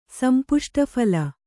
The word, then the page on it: ಸಂಪುಷ್ಟಫಲ: kannada